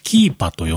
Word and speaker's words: Keepa: で、あ、そうそう、あれを使おうということで、僕ね、 iPhone の中と iPad の中に入れてるアプリがありまして、そのアプリの名前がですね、キーパと読むんでしょうかね。 K-E-E-P-A、キーパ。